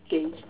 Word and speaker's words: Gauge: It's Gauge. Gauge, thanks, Chaplain. I'm reading it in Xhosa. Yeah, okay. So my chair was the teacher that corrected me is Gauge.